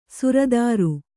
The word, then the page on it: ಸುರತರು: Kannada; sura taru